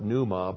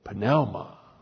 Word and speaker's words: pneuma: Now, you call it pneuma, but there's no silent letters in Greek. That no Greek would ever say pneuma. He would say pneuma.